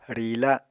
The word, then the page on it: riila: Apatani